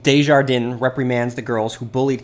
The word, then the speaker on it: Desjardins: I was pronouncing it Desjardins, but now I know the French pronunciation, Desjardins. Okay, maybe I can't perfectly do it.